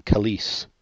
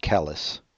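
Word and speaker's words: Kelis: And my next pick, I believe you pronounce her name Kalise, not Callis.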